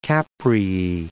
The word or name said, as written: Capreae